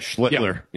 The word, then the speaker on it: Schlittler: I've seen probably Skeens pitch twice on TV, and I've seen, how you say his name, Schlittler? – Yeah, Schlittler.